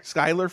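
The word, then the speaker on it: Schuyler: Schuler Fisk. Am I saying that right? Catherine O'Hara. – Skylar Fisk.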